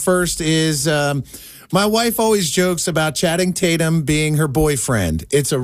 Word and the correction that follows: Channing Tatum: Channing Tatum.